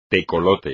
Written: tecolote

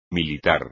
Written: militar

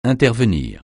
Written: intervenir

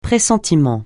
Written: pressentiment

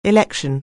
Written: election

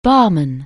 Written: Basel

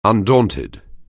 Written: undaunted

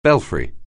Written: belfry

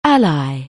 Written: ally

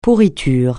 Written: pourriture

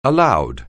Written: aloud

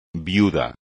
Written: viudo

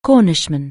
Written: Cornishman

